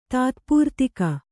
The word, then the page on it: ತಾತ್ಪೂರ್ತಿಕ: tātpūrtika